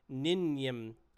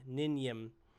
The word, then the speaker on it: Ninym: Nim, I can't even pronounce her name. Ninyim. N-I-N-Y-M, Ninyim, Ninyim, and he's like, you know, his goofy self to her.